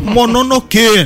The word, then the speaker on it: Mononoke: Mononoke, né? Tem que subir, inclusive, a sílaba tônica final, seus chatos, mas tudo bem.